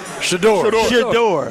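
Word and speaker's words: Shedeur: The Shore Sanders? – Shador. – Yeah, Shador Sanders.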